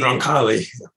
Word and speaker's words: Roncalli: of Angelo Roncalli, if I'm not butchering his name, who later become John the 23rd, right? And he actually... – Roncalli.